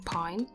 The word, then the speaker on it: pagne: Lots of these beautiful dresses and outfits that are made of pine. Which is like, not the tree pine, not that, but it's spelled P-A-G-N-E.